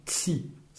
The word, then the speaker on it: ci: Il existe bien un tutoiement, ça se dit tsi.